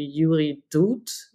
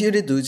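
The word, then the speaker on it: Yuri Dud (Юрий Дудь): So it was in a conversation with, I hope I pronounced the name correctly, Yuri Dud. Is that? – Yes. His name is Yuri Dud.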